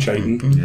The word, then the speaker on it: chitinous: You know, until recently, I didn't know that I mispronounced chitinous. I always thought that the CH was the ch sound. – Oh, like chitin.